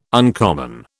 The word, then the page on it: uncommon: British English